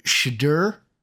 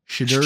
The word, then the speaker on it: Shedeur: How how, how do you say uh, uh, uh Sanders' first name? – Uh, Shedeur. – Shedeur Shedeur, Shedeur Sanders. Okay. Yeah, because I I've been butchering that for the last however long.